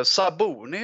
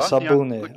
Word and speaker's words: Sabuni: Eh, Malmström eller det här eh, Symbeni, jag kan inte uttala efternamn, vad heter hon? – Uh, Saboni heter ja, hon va? Sabuni, ja, ja precis.